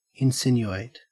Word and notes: insinuate: Australian English

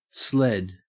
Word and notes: sled: Australian English

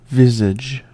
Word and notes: visage: American English

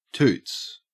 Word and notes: toots: Australian English

